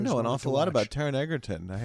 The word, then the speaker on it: Taron Egerton: He's got Taron Egerton. Oh, I got the name right. – Yes.